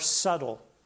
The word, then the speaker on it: subtle: First, generally, the devil, his attacks, because he attacks even us, his attacks are subtle. Subtle. And you remember how to spell the word subtle, that it has a silent B in it, a subtle B inside the subtle.